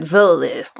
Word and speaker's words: Wehrle: He saw me writing it, and he was like, he said, Werle. And I was like, okay. But right. it's Werle's the family name. It's how the family pronounces okay.